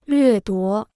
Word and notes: 掠夺: llüè duó

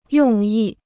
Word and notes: 用意: yòng yì

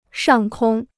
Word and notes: 上空: shàng kōng

